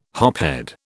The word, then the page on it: hophead: British English